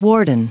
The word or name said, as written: warden